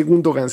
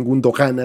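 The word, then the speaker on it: Gündoğan: Pero ese Gundogan se escribe con una G que tiene un sombrerito al revés encima y esa mierda no se pronuncia.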